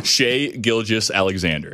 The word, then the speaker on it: Shai Gilgeous-Alexander: Shai Gilgeous-Alexander.